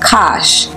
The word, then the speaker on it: Kash: Historically, Cush is spelled beginning with a C or a K, and alternatively pronounced Kash, K-A-S-H, as he was called in Hebrew,